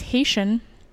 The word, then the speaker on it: Haitian: While in prison, his cellmate, who was Haitian, that's how you say it, right? – Yeah.